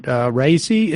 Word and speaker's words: Raisi: How much of the regime's reaction has been muted because you have the Iranian president, Raisi, am I pronouncing that correctly? Yeah.